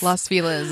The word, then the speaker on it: Los Feliz: Los Feliz is how it's pronounced.